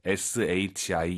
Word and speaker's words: 时: 지금 왕 선생님께서 발음하신 샹克时 이거 时입니다 아니에요. 뭐뭐 할 때, SHI 이성이에요. 검색해 보세요.